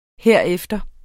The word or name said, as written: herefter